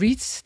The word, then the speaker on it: REITs: Jeg synes sjældent, der bliver snakket om REITs i programmet, altså REITs, Real Estate Investment Trust. – REITs. – Nå, er det REITs, det udtales? – Ja, det vil jeg, som jeg kalder.